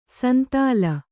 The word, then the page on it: ಸಂದಲಿ: Kannada